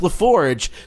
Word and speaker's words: LaForge: In fact, his last name, although pronounced LaForge, spells laugh orgy.